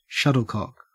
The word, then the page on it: shuttlecock: Australian English